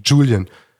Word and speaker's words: Julian: Julian wendet einer ein, das J englisch ausgesprochen, also Julian. Julian, Bruder, ja, Julian ist der Einzige, der kommt aus Kanada, wieder Gelächter.